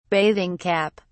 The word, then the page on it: bathing cap: British English